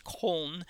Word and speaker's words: Köln: We call it Cologne, but it's pronounced Köln.